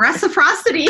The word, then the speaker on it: reciprocity: Oh, I can't pronounce that term, reciprocity. – That's it.